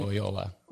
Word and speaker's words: Loyola: Loyola.